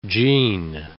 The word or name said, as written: jean